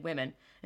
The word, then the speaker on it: women: The plural as written seems to be womb-men, but we pronounce it, this is weird, well, no, it's not weird, I get it, W-I-M-E-N, women, and so it ought to be written, for it is from the Saxon with man, comma, wife man, I don't even know.